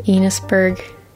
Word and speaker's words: Enosburg: Enosburg. Enos rhymes with Venus.